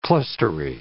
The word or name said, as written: clustery